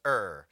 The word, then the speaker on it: err: Now I might say err. Technically it's pronounced err.